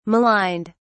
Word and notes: maligned: British English

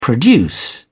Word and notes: produce: British English

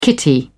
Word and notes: kitty: British English

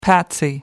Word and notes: patsy: American English

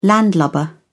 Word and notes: landlubber: British English

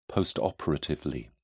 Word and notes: postoperatively: British English

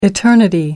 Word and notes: eternity: American English

